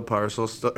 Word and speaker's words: Parcells: You even pronounced it Parcells. Like, here's your parcel, right?